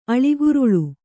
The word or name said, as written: ಅಳಿಗುರುಳು